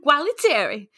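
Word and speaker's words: Gualtieri: You got to say it in a Jersey accent. – Gualateri. And earlier, Salvatore. – There you go. See, it sounds so much better.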